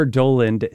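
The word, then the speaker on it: Dowland: People have used the title of this piece to determine how you should pronounce his name. It's Semper Doland, Semper Dolens, played here on the lute by Nigel North.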